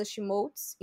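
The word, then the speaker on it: Schmolz: Vocês me encontram como arroba ou barra Fernanda Schmoltz em todos esses lugares. Lembrando que meu sobrenome se escreve S-C-H-M de Mickey O-L-Z.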